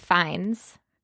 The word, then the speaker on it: Fiennes: And here I am thinking it's Ralph Fiennes. Is it Fiennes? Phineas? Finn? – I think it's Ralph Fiennes. – How? How is it Ralph Fiennes? R-A-L-P-H.